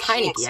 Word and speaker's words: Heinicke: I probably said that name wrong, by the way. – I think it's Heinicke. I think he got it right.